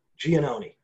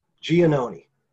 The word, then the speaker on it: Giannone: How do we pronounce your last name, Nick? – Giannone. – Giannone. – Yep. – This is going to be a tough one for me today. I'm tongue-tied already. Giannone. – Yeah, that's good enough. I'm not sure if that's proper myself, but that's how I learned to pronounce it.